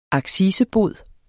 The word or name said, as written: accisebod